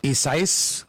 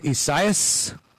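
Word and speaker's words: Isaias: So I guess it's Esaias or Esaias. Three or four syllables, kind of like Louisiana, Louisiana.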